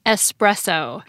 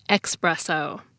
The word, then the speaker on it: espresso: And the big language thing to note is that it's pronounced espresso, not expresso.